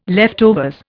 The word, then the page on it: leftovers: Indian